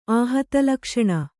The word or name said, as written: ಆಹತಲಕ್ಷಣ